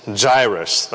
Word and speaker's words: Jairus: How do you say the guy's name in English? – Jairus. – Jairus. Jairus. Okay. In Korean, it's Yairo. Yairo. And in the Greek, it's Iaros.